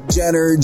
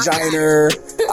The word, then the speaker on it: Gener: You were like, people say Jenner, Jenner, Jenner, not Jiner.